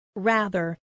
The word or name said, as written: rather